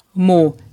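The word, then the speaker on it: Moët: Als sich aber André darüber lustig machte, dass die Leute den Champagner Moet falsch aussprechen, es wird tatsächlich Moet ausgesprochen. Er soll sich also okay.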